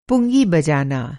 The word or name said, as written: पुंगी